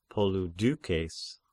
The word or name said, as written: Polydeukes